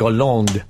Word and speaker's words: Hollande: How would you pronounce it? – I think Hollande. – That's okay. Okay, I'll have that.